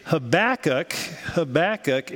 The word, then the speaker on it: Habakkuk: It's thought to have Akkadian or Hebrew roots, but actually Habakkuk is the preferred pronunciation by most scholars, so shame on you, most of you.